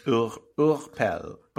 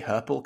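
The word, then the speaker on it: Hurepel: I think in French it's pronounced Urpel, but I'm going to say Herpel because it's easier to say.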